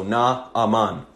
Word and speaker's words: Naaman: So it's actually the syllables are set. No, it would be three syllables. So Naaman.